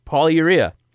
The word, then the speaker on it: polyurea: Did you say urea? – Polyurea. And are you sure that you're saying that right? Polyurea? – Yep.